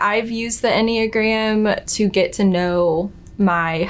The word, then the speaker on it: Enneagram: Yeah, I mean, Enneagram, first and foremost, rhymes with pentagram.